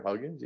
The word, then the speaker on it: Huggins: I, I, don't know what's going on with with Huggins or Huggins. Yeah, I said that right.